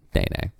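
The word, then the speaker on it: nēnē: And it is pronounced Nene.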